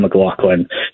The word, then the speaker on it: McLaughlin: First of all, McLaughlin, like Sarah okay. McLaughlin. He clarified that when we talked to him. So I was pronouncing it wrong, too.